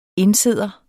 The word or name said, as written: indsidder